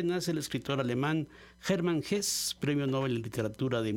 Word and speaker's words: Hesse: Así es, un alemán o un alemanófilo te diría que es Hesse.